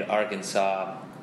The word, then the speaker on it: Arkansas: it had been my theory that is the state named Arkansas, but it's spelled like Arkansas.